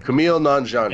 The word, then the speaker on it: Nanjiani: Kumail Nanjiani.